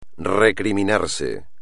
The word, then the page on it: recriminarse: espagnol